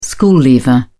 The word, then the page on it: school leaver: British English